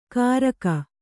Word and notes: ಕಾರಕ: Kannada

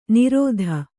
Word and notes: ನಿರೋಧ್: nirōdh

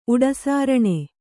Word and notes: ಉಡುಸಾರಣೆ: Kannada; uḍusāraṇe

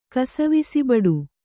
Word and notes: ಕಾಸವಿಸಿಬಡು: kāsavisibaḍu

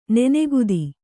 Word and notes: ನನೆಗುದಿ: nanegudi; Kannada

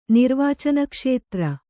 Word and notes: ನಿರ್ವಾಚನಕ್ಷೇತ್ರ: nirvācana kṣētra

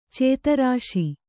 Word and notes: ಚತುರಾಶಿ: caturāśi

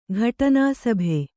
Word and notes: ಘಟನಾಸಭೆ: ghaṭanā sabhe

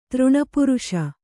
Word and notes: ತೃಣಪುರುಷ: Kannada

